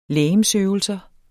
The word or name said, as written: legemsøvelser